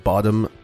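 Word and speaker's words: Bodom: And welcome to this true crime episode all about the Lake Bottom murders. That's B-O-D-O-M, not B-O-T-T-O-M. – But we will be... – Pronouncing it like Bottom.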